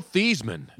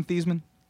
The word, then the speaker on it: Theismann: Joe Theismann. – Yes, right. – His mom used to rhyme with Heisman. Yep.